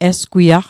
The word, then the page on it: esgwiaq: Mi'gmaq